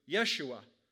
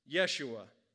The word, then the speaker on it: Yeshua: In the Old Testament, Joshua, which is pronounced in Hebrew, Yeshua, leads Israel through the waters of the Jordan and into the Promised Land.